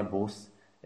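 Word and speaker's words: busz: Takže pokud chcete něco vyslovovat s, tak musíte psát sz, takže z toho důvodu se tam píše například bus z, aby se to mohlo číst normálně bus, jakože autobus.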